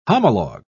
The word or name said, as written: homologue